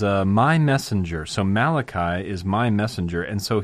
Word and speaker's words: Malachi: On the docket today is the first chapter of Malachi, or Malachi. No, definitely Malachi. Malachi, I guess you could say in Hebrew. I'm just looking at the first verse here. Malachi would be the way you pronounce that.